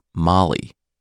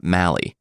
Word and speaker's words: Mali: The name of Josh's country is pronounced Mali, not Mali.